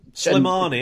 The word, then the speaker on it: Slimani: I've got enough views for Slimani, I think it's pronounced. I'll get that one. But yeah, Slimani, isn't it? – Slimani.